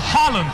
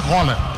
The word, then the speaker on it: Haaland: Tá rolando uma polêmica aí da pronúncia do nome dele. – Haaland.